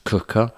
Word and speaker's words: Quooker: Because they misspelled beer, B-E-R, B-E-E-R, 52. We're also sponsored by the lovely people at Cooker, spelt Quooker. But pronounced Cooker, who get boiling hot water, instant boiling water.